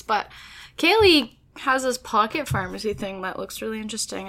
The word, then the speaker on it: Farmacy: They spell it F-A-R-M-A-C-Y. – Pharmacy.